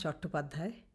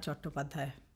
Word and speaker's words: Chattopadhyay: So in that way, at home, my name is Ananya Chattopadhyay, because I'm Bengali, so Ananya is the way we would say it. And Chatterjee is because the British couldn't pronounce Chattopadhyay, so they made it Chatterjee.